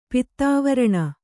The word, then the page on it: ಪಿತ್ತಾವರಣ: pittāvaraṇa